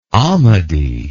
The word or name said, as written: Ahmadi